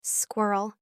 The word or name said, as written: squirrel